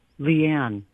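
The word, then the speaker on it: LeAnn: Leanne. – Leanne. How do you spell that? L-E-A-N?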